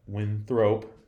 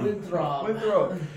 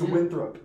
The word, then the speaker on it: Winthrop: I got Winthrop winning this one. – Winthrop. – Did I say it wrong again? – It's Winthrop.